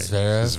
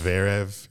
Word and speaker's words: Zverev: Zever. I can't ever get his name right. – Zverev. Zverev.